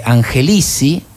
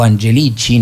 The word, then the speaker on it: Angelici: Però è un delfino perché si dice che Angelici, o Angelici, no, nella pronuncia italiana, come volete, è quello che fa il lobby giudiziario per Mauricio Macri nei diversi tribunali dell'Argentina.